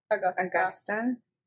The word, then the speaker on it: Augusta: Well, there's in Georgia, like, Augusta, that golf course. Am I saying it right? – Augusta? – Yeah.